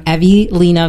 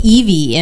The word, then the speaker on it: Evy Lena: We have a very special guest with us today, Katrina from Evelina Vintage. I say that right? – You did. – We kept saying Evie in our heads. And I thought it was Evie. – Because of Evelyn.